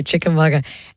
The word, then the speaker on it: Chickamauga: Chickamauga. Yeah, it's a Cherokee word. – Okay, Chickamauga.